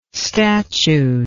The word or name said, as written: statued